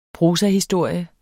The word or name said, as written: prosahistorie